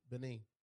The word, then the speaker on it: Benin: Benin.